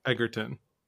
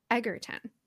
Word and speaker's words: Egerton: I think it's Egerton. – Egerton. Okay. Yeah. Thank you.